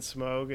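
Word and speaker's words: Smough: At least with Ornstein and Smog, it's just the is it Smog or Smog? I don't know. Smog is probably it, right? – Smog. Silent G. – Silent G. Smo. Ornstein and Smo.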